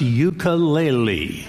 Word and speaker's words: ukulele: Peter's first question to Taimane: How the hell do you pronounce ukulele? – Ukulele. – Ukulele. – Uku. – Uku. – Lele. – Lele. – You got it.